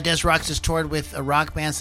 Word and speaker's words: Des Rocks: But Daniel Rocca, also known as Des Rocks, as he pronounced it, is an American rock musician from Rockville Center.